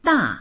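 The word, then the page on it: da4: Mandarin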